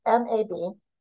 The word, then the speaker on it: Mabinogion: The bulk of his story is found in the Mabinion, which I butchered. It's Mabinogion. And it is a collection of Welsh myths, legends, stories.